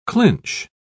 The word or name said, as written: clinch